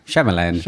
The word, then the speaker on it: Shyamalan: Shyamalan.